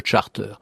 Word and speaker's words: charter: C'est un mot anglais qu'on a toujours prononcé plus ou moins à l'anglaise. On fait sonner la consonne finale, un hein, ter, et puis parfois, même au début, on dit charter ou charter.